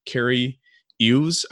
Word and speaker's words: Cary Elwes: The main character is Wesley, played by Cary Elwes, I think is how you actually pronounce his name. – Yeah.